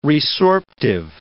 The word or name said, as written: resorptive